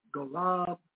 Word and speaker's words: Golub: We're going to jump to a track by Jeff Golub. Now, some people pronounce it Golub. It's G-O-L-U-B.